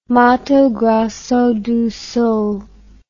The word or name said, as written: Mato Grosso do Sul